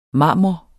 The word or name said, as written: marmor